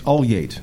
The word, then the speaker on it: Ulyate: It's Ulyate. – Ulyate. Ulyate. I'm going to write this phonetically. – Ul as an ultimate. Yate is a gate.